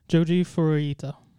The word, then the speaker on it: Joji Furuta: I would pronounce it as Joji Furuta.